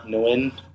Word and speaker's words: Nguyen: My IG and everything that's at Andy the Nguyen or Andy the Win, whatever. – And you spell it just for, A-N-D-Y-T-H-E, last name spelled N-G-U-Y-E-N.